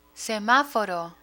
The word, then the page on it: semaforo: spanish